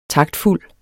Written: taktfuld

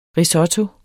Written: Risotto

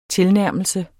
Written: tilnærmelse

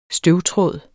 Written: støvtråd

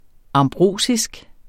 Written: ambrosisk

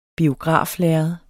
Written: biograflærred

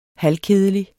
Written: halvkedelig

